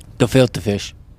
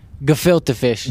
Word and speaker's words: gefilte fish: Gefilte fish. – How do you say? – Gefilte fish. – Gefilte fish. Oh, not to be confused with filter fish.